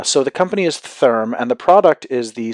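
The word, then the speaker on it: Thyrm: The company name, it's spelled T-H-Y-R-M. I think it's pronounced Therm, so I'm going to go with that.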